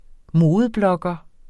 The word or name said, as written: modeblogger